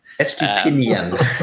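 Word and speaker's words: Estupiñán: Estepinian or whatever his name is. I'm going to butcher it. Purvis. We'll just call him Purvy from now on. – Estupinian. – Estupinian. Yeah.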